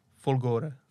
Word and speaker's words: Folgore: No a nad tím vším ještě stojí nejsilnější verze Fol- Foglore? Folgore. Folore. Folore. Dobrá, dobrá, tak to je to správná výslovnost.